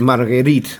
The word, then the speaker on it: Marguerite: Angélique Marguerite Lebrusier du Coudray. – Muchas R's ahí, para ser francesa. – Marguerite, como los franceses pronuncian la R.